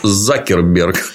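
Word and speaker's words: Zuckerberg: Удивил меня, оказывается, Цукерберг по-английски. Он Закерберг. И Цукерберг.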